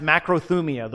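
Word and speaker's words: macrothumia (μακροθυμία): And it's, it's actually in Greek, it's pronounced macrothumia, the word that is used in Galatians 5 and in the passage we're about to read here in Colossians 3.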